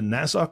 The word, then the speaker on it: Nassau: He is a former president of the Nassau County Optometric Society.